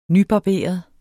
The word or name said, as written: nybarberet